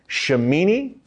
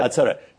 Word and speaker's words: Shemini Atzeret: It's a mysterious day called Shemini. Am I pronouncing it right? – Yeah, pretty much. – Shemini Atzeret. – Atzeret.